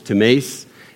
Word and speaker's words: timēs: The word is T-I-M-E-S. In Greek, it's pronounced timēs.